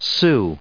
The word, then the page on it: Sioux: American English